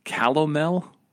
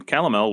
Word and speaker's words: calomel: And so the doctor prescribed him, um, I think I'm saying this right, calomel.